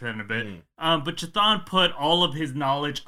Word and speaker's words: Chthon: How do you say this? Chthon? – I think it's Chthon.